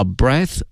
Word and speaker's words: breath: 我们知道呢这个 breath 是呼吸的名词，然后呢后面再加上 fresh air，新鲜空气，呼吸了一口啊，这是英文的直译了，转一下呢我们就可以说一股新鲜的空气， A breath of fresh air。同时啊，呼吸这个词因为呢它的词性是名词，因此它的发音是 breath，而它的动词呢是 breathe， breathe。